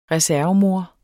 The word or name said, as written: reservemor